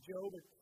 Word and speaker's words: Job: You'll find the book of Job. It's spelled the same way as Job. It's J-O-B.